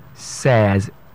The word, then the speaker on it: says: And that's the word says. Says. S-A-Y-S. Says. That is the way you pronounce the word.